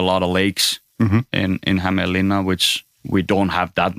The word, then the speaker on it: Hämeenlinna: Hämeenlinna, yeah.